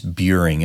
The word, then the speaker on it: Buring: I know that's something none of us like, you know, so my last name is Buring. It's pronounced like the word curing with a B in front of it.